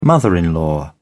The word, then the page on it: mother in law: British English